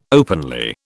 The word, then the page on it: openly: British English